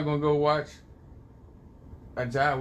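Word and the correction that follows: A'ja: Wilson, or how you say her name? – Asia.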